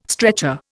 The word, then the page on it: stretcher: Indian